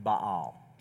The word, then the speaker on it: Baal: Of the Bible, B-A-A-L, it's technically pronounced Baal, just so if you want to like talk to a Bible scholar, it's Baal. But Baal's fine.